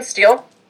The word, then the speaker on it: Steele: So my name is Morgan Steele, and I was the planner assigned the environmental review for the proposed development within Forest Park.